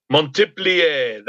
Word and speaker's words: Montpelier: Montpellier. That's how I've heard it pronounced, if you want to get all French with it.